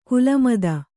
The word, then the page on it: ಕುಲಮದ: kulamada